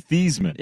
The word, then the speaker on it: Theismann: He was originally Theismann. – Yeah, but you got to change it. Theismann's no good. – Yeah, it doesn't rhyme with Heisman when you're in Notre Dame. – Exactly. You need to be Joe Theismann, who won the Heisman.